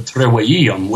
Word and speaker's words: trivium: If you'd like to discuss the trivium, which is actually how it's pronounced, because Jan Ervin doesn't actually speak Latin, so he doesn't even know that there's no such V sound in the Latin language. When you see a V in Latin, you pronounce it like a W.